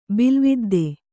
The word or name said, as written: ಬಿಲುವಿದ್ದೆ